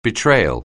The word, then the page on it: betrayal: anglais